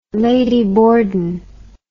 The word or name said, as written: Lady Borden